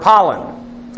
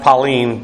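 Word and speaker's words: Pauline (πάλιν): She began, and then you have this adverb pollen. Again. Actually pronounced Pauline, but the English eye doesn't tend to pronounce the iota as E like we should.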